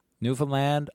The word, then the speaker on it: Newfoundland: Newfoundland, understand. That's it. Not any harder than that. – It is a little bit harder than that, and I'll tell you some of the history about how Newfoundland is pronounced.